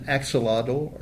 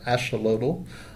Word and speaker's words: axolotl: Well, the design is it represents an axolotl or axolotl, depending on your pronunciation, which is a salamander that is native to the central part of Mexico.